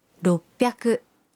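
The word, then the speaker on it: roppyaku: 600 se convierte en ropyaku.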